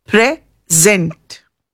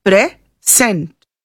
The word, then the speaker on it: present: In the word present, when it is read as a verb, the stress is on the second syllable. It is present. While if the word present is used as a noun, the stress is on the first syllable, present.